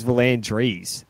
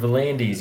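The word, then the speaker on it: V'landys: I thought it was Vlandries. – No, Vlandis.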